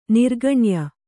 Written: ನಿರ್ಗಣ್ಯ

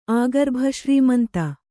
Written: ಆಗರ್ಭಶ್ರೀಮಂತ

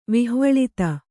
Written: ವಿಹ್ವಲಿತ

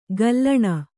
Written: ಘಲ್ಲಣಿ

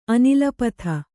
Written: ಅನಿಲಪಥ

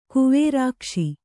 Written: ಕುವೇರಾಕ್ಷಿ

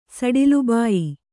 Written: ಸಡಿಲುಬಾಯಿ

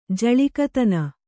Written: ಜಳಿಕತನ

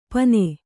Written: ಪನೆ

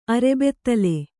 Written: ಅರೆಬೆತ್ತಲೆ

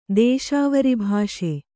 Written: ದೇಶಾವರಿಭಾಷೆ

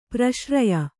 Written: ಪರಿಸರ್ಯೆ

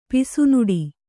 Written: ಪುಸಿನುಡಿ

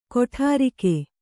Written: ಕೊಠಾರಿಕೆ

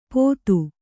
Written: ಪೋತು

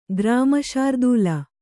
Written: ಗ್ರಾಮಶಾರ್ದೂಲ

